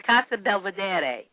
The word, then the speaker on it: Casa Belvedere: I'm sorry, Casa Belvedere. – You can definitely Google it. – I had realized I was pronouncing it in English, so yes.